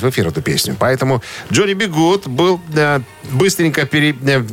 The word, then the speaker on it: Johnny B. Goode: Полное имя Джонни Бигуд произносится на английском так же, как и фраза э, «Джонни, запятая, Бигуд», типа «Будь, Джонни, будь хорошим».